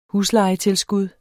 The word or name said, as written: huslejetilskud